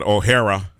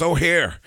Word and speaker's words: O'Hare: or as if you were born in Chicago and didn't know the proper pronunciation, you said O'Hara. I'm going to O'Hara Airport. It's O'Hare.